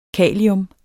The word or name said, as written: kalium